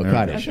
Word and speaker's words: Kaddish: Please say Kaddish for me. – There you go, Kaddish.